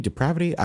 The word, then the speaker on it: depravity: I see some true depravity. But I also know that some people, like, did I say that word? Depravity? Depravity? I don't know. – You got depravity? Depravity? It rhymes with McCavity.